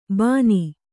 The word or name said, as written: ಬಾನಿ